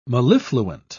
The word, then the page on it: mellifluent: British English